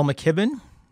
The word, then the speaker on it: McKibben: I recently listened to a podcast with you and Bill McKibben. Is that how you pronounce his name? – Yes.